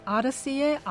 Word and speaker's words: Odyssey: 스페이스 아더시의 아더시, O-D-Y-S-S-E-Y, 아더시, 이 말은 긴 여정을 뜻하는 단어인데요. 이 아더시와 발음이 비슷한 어더티를 갖다 붙인 겁니다.